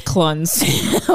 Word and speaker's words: Clunes: played by Martin Clunes. I'm assuming that's how you say his name. – No, it's Clunes.